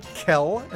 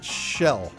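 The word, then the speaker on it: Kjeill: E-I-L-L. – I've heard it pronounced two ways. I know two people with that name, one of whom pronounces it Kel, and the other one pronounces it Shell.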